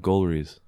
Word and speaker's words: Golriz: Golriz. – All right. Can you spell that one too? – Yeah, G-O-L-R-I-Z.